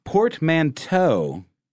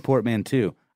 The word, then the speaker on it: portmanteau: Portmanteau pronounced correctly. And I know it was me, Ben. It was me. I said portmanteau. I don't know why. I know how to pronounce it. I just got caught up in the moment.